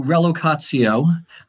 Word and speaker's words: relocatio: So relocatio, you pronounced it correctly.